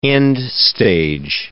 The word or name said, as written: end-stage